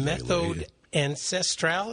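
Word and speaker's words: Méthode Ancestrale: Méthode Ancestral, is that how you pronounce that? – Yeah.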